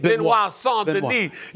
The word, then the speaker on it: Benoit Saint-Denis: Benoit Saint-Denis.